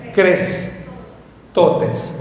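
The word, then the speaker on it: Crestotes (χρηστότης): Es, mire, se le escribo cómo está y ahí abajo le pongo cómo se pronuncia. Crestotes. Diga conmigo, crestotes. No, pero dígalo, crestotes.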